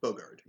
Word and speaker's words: Bogarde: Bogarde? – Bogarde, yeah. – I mean, I am a Bogart, so, yeah. – But I don't, we don't, my Bogarts don't spell it like this Bogarde.